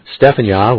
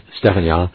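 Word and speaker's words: Tsephanyah: Stephan Yah at yahweh.name. Once again, Stephan Yah, which is spelled with a T, T-S-E-P-H-A-N-Y-A-H, Stephan Yah,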